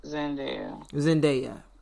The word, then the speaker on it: Zendaya: And it's the singer Zendaya. I don't know how to pronounce her name. – Yes, Zendaya.